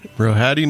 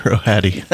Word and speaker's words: Rohadi: Rohadi Nagassar. Yep. – That's right. In your book, you say it is pronounced exactly like it's spelled. – Yeah, Rohadi.